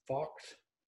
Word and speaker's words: Focht: Here with Kara, and your last name is Focht? Focht? – Yeah, you pronounce it Foch. – Foch? – Yeah, that spelling doesn't help anyone out.